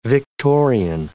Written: Victorian

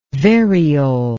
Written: variole

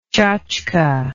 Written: chotchke

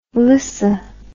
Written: lyssa

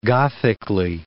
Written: gothically

